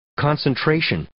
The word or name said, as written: concentration